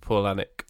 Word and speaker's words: Palahniuk: It's pronounced Palahniuk.